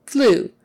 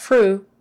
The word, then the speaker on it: flu: Flu. That's an easy one. Flu. Or influenza. Flu. Make sure it's an L, not an R. Tongue up behind the teeth for L. Flu. Flu. Not fru. Fru.